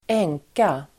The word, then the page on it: änka: [²'eng:ka]